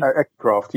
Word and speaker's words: Accucraft: Maybe Accucraft locally? – Accucraft, yeah.